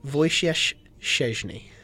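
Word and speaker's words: Wojciech Szczęsny: the correct pronunciation is Wojciech Szczesny.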